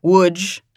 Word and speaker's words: Łódź: So Łódź, I was calling Lodz, and I had to call my friend, who's Polish, to get the pronunciation, to ask her, like, how do you say these things?